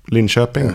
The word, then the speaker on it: Linköping: Is that Linköping? – Linköping. Yeah. Good pronunciation.